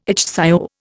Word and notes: ichthyo: Indian

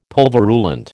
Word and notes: pulverulent: American English

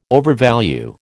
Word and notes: overvalue: American English

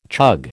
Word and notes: chug: American English

